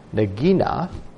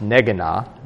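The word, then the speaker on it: Neginah: From Psalm 61, it says, to the chief musician upon Nagina, a psalm of David. And that heading, actually, I think it's pronounced Negana.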